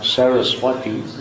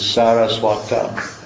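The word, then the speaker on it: Sarasvata: You'll notice it's not Saraswati, as people say. It's actually, in the locative case, it's Saraswata. The long A,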